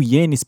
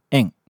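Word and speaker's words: en (円): Na parte que ela fala que é 1000 yenes por pessoa, toma um pouquinho de cuidado. A pronúncia da moeda japonesa não é yenes, como a gente fala aqui, é en. Agora repete depois de mim.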